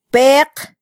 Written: peq

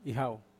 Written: hau

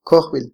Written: kojbil

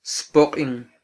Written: spoqin

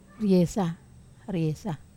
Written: resa

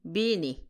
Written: bini

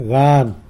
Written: gan

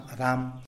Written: rdam

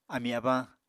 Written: amí-abañ

